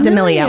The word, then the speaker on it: D'Amelio: So you could get served, like if I was swiping, I could get served like Charlie, how do you say last D'Amelio. – Name? D'Amelio. D'Amelio.